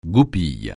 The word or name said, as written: goupille